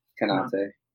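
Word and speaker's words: Konaté: Yeah, Konate.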